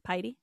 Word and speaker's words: piety: Is that how you say it? Piety? It's piety, right? – Yeah, piety.